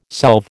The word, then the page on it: solve: American English